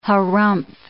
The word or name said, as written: harrumph